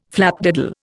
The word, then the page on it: flapdoodle: Indian